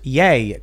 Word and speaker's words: Ye: Yeah. – Small yield nuclear weapons, right? – Ivan Ooh says, Tim, stop saying yay as ye. Or it's pronounced yay, Kanye West. – Yeah, it's yay, I think.